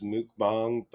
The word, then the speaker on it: mukbang: Oh, I learned that there's a Korean audio podcast that is pronounced mukbang, but it's spelled like mukbang.